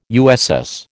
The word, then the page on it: USS: American English